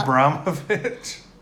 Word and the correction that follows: Ibrahimovic: I don't even know how to say his name. – Ibrahimovic. – Either way. – Ibrahimovic. – I think that's closer than what I was.